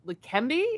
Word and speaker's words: Leqembi: And the FDA granted full approval of Leqembi. Is that how you pronounce it? – Mm-hmm. Yeah.